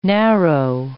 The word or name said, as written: narrow